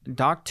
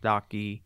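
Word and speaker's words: Dok2: Doc too, but he's in Korean it's pronounced Doc-y.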